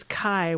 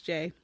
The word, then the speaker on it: Jai: It's pronounced Jay. – Mm. – Oh, is it? – Yeah. – Mm-hmm. I always thought it was Kai when he's in the Queer Eye for the Straight Guy.